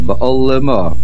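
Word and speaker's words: Ba'olama (בעלמא): The word Ba'olama, the lamed has a shivana, Ba'olama.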